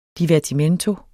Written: divertimento

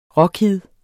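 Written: RÅKID

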